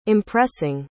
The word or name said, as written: impressing